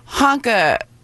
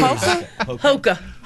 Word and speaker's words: Hoka: Hanka, right? Hoka. Is that how you pronounce it? Hanka? – Oh, there's some honking shoes. – Hoka.